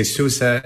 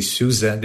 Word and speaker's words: SUSE: So in Czech Republic, you would say SUSE. In Germany, some people say SUSE. Depends also on the region. They really pronounce Z for the hidden S.